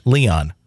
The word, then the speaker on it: Lyon: By the way, it's pronounced Leon.